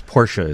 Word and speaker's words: Porsche: Is Porsche is the correct pronunciation on that. And then another car company, H-Y-U-N-D-A-I.